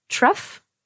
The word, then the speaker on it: trough: Euh, euh, deux mots qui se ressemblent énormément, par exemple euh, T-R-O-U-G-H et T-H-R-O-U-G-H se prononcent de façon complètement différente. Truff et through. Donc le O-U-G-H peut avoir deux sons complètement différents. F comme dans truff et OU comme dans through.